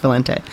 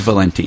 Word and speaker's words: Valente: So everyone's being shocked that my name is not pronounced Valente. – So it's Valenti.